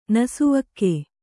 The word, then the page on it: ನಸುವಕ್ಕೆ: nasuvakke